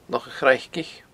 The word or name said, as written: Loch a' Chraicich